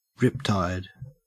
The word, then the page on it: riptide: Australian English